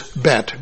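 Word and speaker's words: Beth: Right above verse 9 is Bet, B-E-T-H. H is silent.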